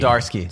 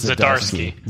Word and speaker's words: Zdarsky: Do you want to say it correctly? – Chip Zdarsky. – Zdarsky. – It's Zdarsky. – Zdarsky. ZD.